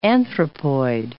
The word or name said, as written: anthropoid